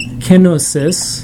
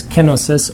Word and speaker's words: kenosis: Kenosis. A lot of times in English people will pronounce it with the accent on the O. The real Greek word is pronounced with the accent on the first syllable.